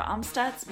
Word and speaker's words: Amstutz: You can find me on all social media platforms at Sandra Amstutz. My last name is spelled AMSTUTZ.